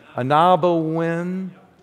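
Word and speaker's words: Ah-Nab-Awen: Ford Museum, which I can never remember how to pronounce, but if you're from here, it starts with an O, right? Anabawin? There we go.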